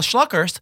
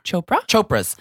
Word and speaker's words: Chopra: So anyway, speaking of the Mickey Mouse Club, Nick Jonas and Priyanka Schlockers. Shanka, how do you say it? Priyanka Schlocker. – Chopra.